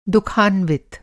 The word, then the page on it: दुःखान्वित: Hindi